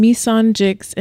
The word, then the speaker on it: Mesonjixx: So Instagram, Mesonjixx, and it's spelled M E S O N J I X X.